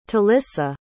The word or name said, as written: telissa